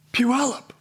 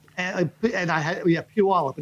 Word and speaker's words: Puyallup: Puyallup. – Puyallup. And I had no idea what they were talking about until I remembered there's a town spelled P-U-Y-A-L-L-U-P, but it's pronounced like it's P-E-W-Y-A-L-L-U-P.